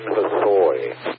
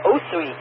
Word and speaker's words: Hosoi: Christian Hosoi. – Never heard of him, but okay, Christian Christ, now I understand. Hosoi, I would have said Osui. I'm glad you pronounced it.